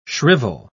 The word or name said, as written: shrivel